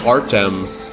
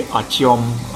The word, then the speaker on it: Artyom: So everyone in America calls him Artem Akulov. Which it's actually Artyom Akulov. – Yeah, Artyom. It's like A-R-T-I-O-M is really the best way to spell it, even though they don't spell it that way.